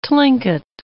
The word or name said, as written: Tlingit